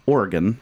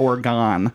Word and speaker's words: Oregon: Oregon. I was corrected on the pronunciation there. It's not Oregon. – No. – That's how some dumbass Floridian kid would say it. It's Oregon.